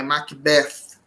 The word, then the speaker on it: Macbeth: Ou Macbeth, que eu escutei que quando você tem essa expressão Mac, a sílaba tônica vai exatamente para a sílaba posterior, né? Como McCartney, McDonald's. Então é Macbeth, como a gente tem que pronunciar.